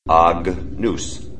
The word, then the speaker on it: Agnus: So if you try to pronounce Agnus Dei, A-G-N-U-S, it would be in classical Latin Agnus Dei. Agnus. However, in Church Latin, it's Agnus. Think of it like lasagna.